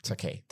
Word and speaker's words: Takei: How do you say his last name, by the way? – Takei. – I should know this. Takei. Thank you.